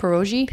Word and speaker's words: pierogi: Pierogi? – Pierogi.